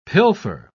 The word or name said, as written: pilfer